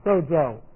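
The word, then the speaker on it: sozo (σῴζω): This is S long O, Z long O, sozo.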